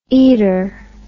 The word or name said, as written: eater